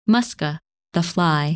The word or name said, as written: Musca